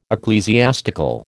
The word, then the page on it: ecclesiastical: American English